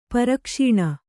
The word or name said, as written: ಪ್ರಕಾಶನ